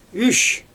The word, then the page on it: yʃ: Xyzyl